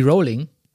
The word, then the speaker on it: Rowling: Rowling ausspricht. Aha. Sagt man Rowling oder Rowling? Sie selbst sagt, es klingt wie Rowling.